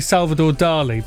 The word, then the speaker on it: Salvador Dalí: I mean, most people say Salvador Dalí, but you're pronouncing it correctly, aren't you? Because there's an emphasis there on the I.